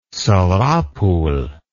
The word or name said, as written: Sarapul